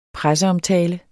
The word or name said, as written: presseomtale